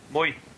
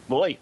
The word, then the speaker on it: Moy: And the other word I said was the guy's name was Moy. And how would you pronounce that in Scottish? – Moy. – Moy. – Moy. Moy. – Cool. – Like oi with an M.